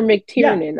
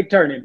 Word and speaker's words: McTernan: McTernan or McTiernan? – Yeah. – I don't want to. No, okay. McTernan. Yeah. Yep. – Mm-hmm. Okay, McTernan.